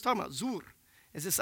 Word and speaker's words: zuwr: And it's this word, zur. Blam, you can't see it, can you? Okay, but anyway, it's spelled Z-U-W-R.